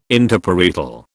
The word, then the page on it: Interparietal: British English